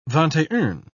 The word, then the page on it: vingt-et-un: British English